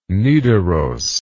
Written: Nidaros

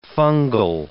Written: fungal

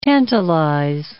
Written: tantalize

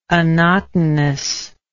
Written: annotinous

